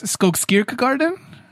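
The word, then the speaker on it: Skogskyrkogården: So my thought for this one is that it's Skogskirka Garden. – That's exactly how I was going to pronounce it.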